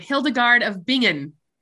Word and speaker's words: Hildegard of Bingen: Hildegard of Bingen.